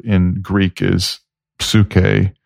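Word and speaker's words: Suke (ψυχή): Like, there is a standard pronunciation that you learn, Suke.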